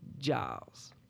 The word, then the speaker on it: Giles: You know how I know it's Giles? Because I grew up near Giles Street. – Right, exactly. Okay? – Spelled the same damn way. – Spelled the same way. – Giles. It's a soft G, more like a J.